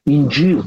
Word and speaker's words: Инджил: Инджил, так они произносят.